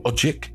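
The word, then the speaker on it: Odjick: Now, it is one from a brilliant album from Lyle Ojik. I think that's how you pronounce his name. It's O-D-J-I-C-K for the surname.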